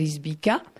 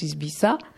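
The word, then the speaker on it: Izbica: Si le nom je le connaissais prononcé à la française Isbica, mais ça se prononce Isbissa.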